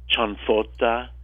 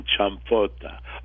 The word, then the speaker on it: cianfotta: and made something that Southern Italians call cianfrotta. C-I-A-N-F-O-T-T-A, or cianfrotta, with an M, C-I-A-M.